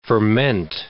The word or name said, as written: ferment